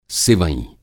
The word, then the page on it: सिवईं: Hindi